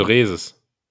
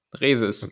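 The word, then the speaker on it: Reese's: Ich dachte Reeses, aber ja, keine Ahnung, man, ich wenn es hier in Deutschland angeboten wird, dann wird es auch deutsch ausgesprochen. – Also Reeses. – Reeses.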